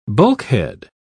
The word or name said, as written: bulkhead